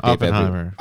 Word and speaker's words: Oppenheimer: You open hemer or something. – The beard I don't even think I said it. – You definitely said it. – Oppenheimer.